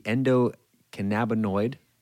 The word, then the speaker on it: endocannabinoid: So let's talk about what is the endocannabinoid did I say that right? – You did. you, you nailed it.